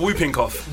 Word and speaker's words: whooping: That's how it's pronounced. – So it's not whooping cough. – Whooping cough. – The W silent.